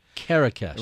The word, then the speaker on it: Kerekes: Kerekes. – Rhymes with Marrakesh.